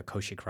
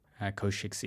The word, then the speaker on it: Kaushik: My name is Dr. Kaushik Ram. Kaushik is spelled K-A-U-S-H-I-K. Ram is spelled R-A-M.